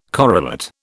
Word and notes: correlate: British English

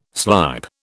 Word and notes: slype: British English

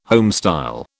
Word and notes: homestyle: British English

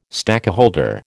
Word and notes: stakeholder: American English

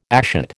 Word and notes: ashet: American English

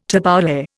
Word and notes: tabbouleh: Indian

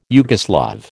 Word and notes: Yugoslav: American English

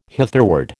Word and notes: hitherward: American English

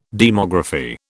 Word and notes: demography: British English

